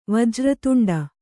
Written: ವಜ್ರತುಂಡ

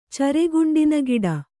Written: ಚರೆಗುಂಡಿನಗಿಡ

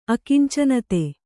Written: ಅಕಿಂಚನತೆ

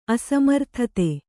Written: ಅಸಮರ್ಥತೆ

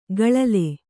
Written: ಗಳಲೆ

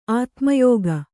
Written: ಆತ್ಮಯೋಗ

